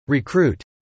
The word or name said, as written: recruit